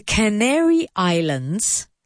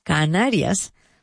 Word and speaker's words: Canary Islands: Now be careful here with pronunciation. I know it's very difficult, but it's así. The Canary, not Canaria, the Canary, Canary como el pájaro, the Canary Islands, sin la S y con la S al final, pero sin la S entre media. So the Canary Islands, land like tierra, and island, I like ojo. That's the pronunciation.